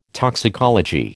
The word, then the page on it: toxicology: American English